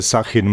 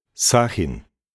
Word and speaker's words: Sahin: Nein, ich heiße Sachin Maxi. – Ah, dann bitte einen Moment. Schreibt man Maxi M-A-G-S-I? – Ja, das ist richtig. – Und wie ist Ihr Vorname? – Sachin. Ich buchstabiere. S-A-H-I-N.